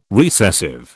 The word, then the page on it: recessive: British English